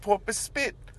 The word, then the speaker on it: Porpoise Spit: I'm like, Pope Spit. Like, because I just think of like Muriel's Wedding. – That's like the town she's from. They say it like 1,000 times in that movie. – Oh. – They're like, Pope Spit. – I see. – And the name of the town is Porpoise Spit.